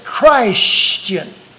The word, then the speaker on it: Christian: But have you ever noticed how Brother Watkins pronounces the word C-H-R-I-S-T-I-E-N? He puts the emphasis on the meaning of the word, a follower of the Christ. He calls it Christian.